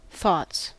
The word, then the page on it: thoughts: American English